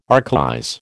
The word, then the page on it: archaize: American English